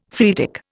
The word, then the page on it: phreatic: Indian